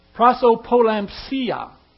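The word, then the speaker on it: prosopolēmpsia: Prosopolampsia is how you pronounce that word. It's P-R-O-S-O-P-L-E-M-P-S-I-A. Greeks need a lot of tin to make their street signs. Prosopolampsia means partiality.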